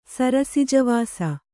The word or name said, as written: ಸರಸಿಜವಾಸ